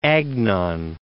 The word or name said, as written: Agnon